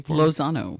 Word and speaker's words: Lozano: Lozano. – Lozano. – Yes, just like it's spelled.